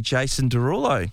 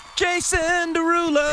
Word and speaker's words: Jason Derulo: We have a double pass, by the way, to see Jason Derulo. – Say it properly. – Jason Derulo. – There we go.